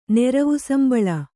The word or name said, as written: ನೆರವುಸಂಬಳ